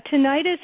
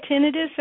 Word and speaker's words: tinnitus: Yeah, tinnitus and tinnitus are actually both used interchangeably. It kind of depends on the area of the country and what you heard the first time.